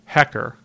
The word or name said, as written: Hecker